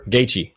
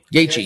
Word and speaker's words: Gaethje: But after the fight was over, his competitor, I can't pronounce his name, Gaethje? Well, whatever. – Gaethje. – Gaethje, yeah, you're talking about Gaethje.